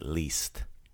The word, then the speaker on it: list: So it looks like the American word list or the English word list, but we pronounce it least.